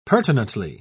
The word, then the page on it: pertinently: British English